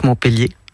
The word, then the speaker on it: Montpellier: Je suis née à Montpellier, que je prononce Montpellier, hein, à l'occitane.